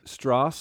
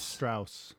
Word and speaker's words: Strauss: Two that I want to bring up, the one that Strauss and Howe, is it Strauss? How would you say that? – My guess is it's Strauss. – Strauss. – Strauss, like Johann Strauss.